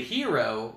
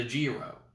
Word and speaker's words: gyro: G-Y-R-O. – Gyro. – It's but gyro. Is it a hero or is it a gyro? – It's a gyro.